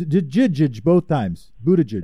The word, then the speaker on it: Buttigieg: Yeah, it, it, it's a hard G, isn't it? – No, I promise. It's Buttigieg. – The first, the first G is a soft G. – Both of them. Both of them are soft G's. He oh, says it. Both soft G. Buttigieg.